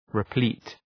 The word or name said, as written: replete